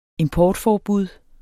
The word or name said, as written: importforbud